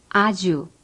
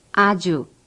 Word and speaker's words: 아주: For example, 아주. – Repeat. – 아주, 아주.